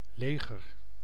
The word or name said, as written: military